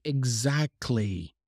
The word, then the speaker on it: exactly: But we don't pronounce the T. We normally say exactly, cli.